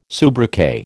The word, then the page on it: soubriquet: American English